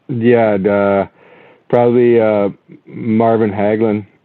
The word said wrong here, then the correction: Hagler: Yeah, Marvin Hagler.